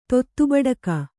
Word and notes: ತೊತ್ತುಬಡಕ: kannada

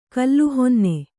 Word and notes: ಕೋಲುಹೊನ್ನೆ: kōlu honne

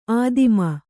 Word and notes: ಆದಿಮ: ādima

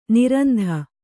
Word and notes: ನಿರಂತ: niranta

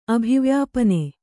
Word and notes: ಅಭಿವ್ಯಾಪನೆ: abhivyāpane

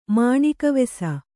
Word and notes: ಮಾಣಿಕವೆಸ: Kannada

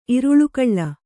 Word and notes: ಇರುಳುಗಳ್ಳ: Kannada

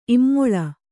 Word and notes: ಇಮ್ಮೊಲೆ: immole

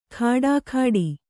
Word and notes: ಖಡಾಖಡಿ: khaḍākhaḍi

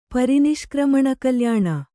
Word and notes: ಪರಿನಿಷ್ಕ್ರಮಣಕಲ್ಯಾಣ: Kannada; pari niśkrmaṇa kalyāṇa

